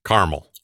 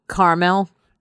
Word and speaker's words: caramel: Why do Americans remove the second A from caramel when pronouncing it? Caramel. – Oh, caramel. Yeah. Caramel. I think that's a regional thing. I've got friends that say caramel and I've got friends that say caramel.